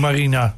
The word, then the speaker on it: Marina: Mariana, zeg ik dat goed? – Nee, net niet. Het is Marina gewoon. – Gewoon Marina. Ik denk, ik probeer de luxe uitvoering, maar net niet gelukt.